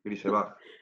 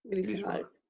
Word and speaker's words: Grisebach: Juanito Grisebeck. Grisebach. – Grisebach.